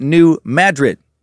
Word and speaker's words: New Madrid: Pro pizza is mediocre at best, and we pronounce it New Madrid because the Spanish pronunciation is too fancy for our tiny little no-good peckerwood brains to comprehend."